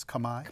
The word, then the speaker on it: Khmer: And we would say Khmer, but the pronunciation, as I understand it, is Khmer. – Khmer, yeah. That's right.